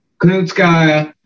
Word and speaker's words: Kunitskaya: Then Kutakana, am I saying that right? – Kunitskaya kept it on the feet and kind of won there.